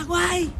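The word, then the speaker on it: wine: Se pronuncia guay. – Eso.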